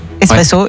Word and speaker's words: espresso: Espresso, ouais. Étymologiquement, on ne prononce pas le X. L'origine du mot vient de l'italien esprimere.